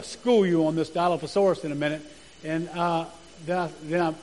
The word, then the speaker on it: Dilophosaurus: And you pronounce it Dilophosaurus.